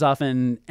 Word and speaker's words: often: You know, the T in often is silent. Just learn that. He's not on there as often, and he's not as active, but you can really connect with us directly there.